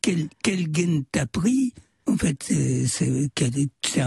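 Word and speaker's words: gen (جن): C'est-à-dire que on utilise le mot, enfin, en arabe égyptien, on dit pas djinn, on dit gen. – Ouais. – Parce qu'on prononce pas le dje, on dit ge à la place.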